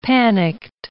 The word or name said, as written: panicked